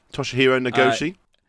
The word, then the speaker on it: Toshihiro Nagoshi: And guys, please do keep your ears open, because if you type in the name Toshihiro Nagoshi, I said that right, didn't I? Toshihiro Nagoshi. – Yes, I believe that is as correct as we could possibly do with our English tongues.